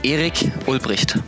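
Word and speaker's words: Erich Ulbricht: Hey guys, so I'm from Germany, so it sounds a bit more aggressive when you say it in German. It's Eric Ulbricht.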